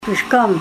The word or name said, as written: prishkom